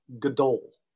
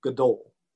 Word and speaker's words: gadol: The Hebrew word for great, you'll see it here in a second, is pronounced gadol, gadol.